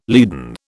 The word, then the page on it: leaden: British English